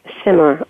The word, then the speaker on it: Simar: Simar, okay.